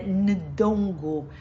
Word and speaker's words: Ndongo: Nzinga Bandi nasceu em 1592 no Ngondo. É muito difícil falar essas palavras porque tem sempre sempre um N mudo no meio, não sei pronunciar, mas é Ndongo.